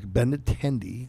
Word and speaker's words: Benintendi: I butcher that name, too. – Benintendi. – Like Benintendi.